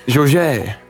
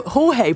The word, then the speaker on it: Jorge: I love that as a very English pronunciation, Jorge Jesus. – How would you say it? Jorge. – Jorge, probably. Surely. Jesus.